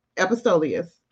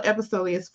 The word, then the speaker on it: Epistoleus: Well, I just want to let you know that she was our past international ooh, Epistoleus. I said I was going to say it the, the, other way, didn't I? See, I'm always messing up. But anyways, that's how I pronounce it.